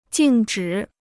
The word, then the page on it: 静止: jìng zhǐ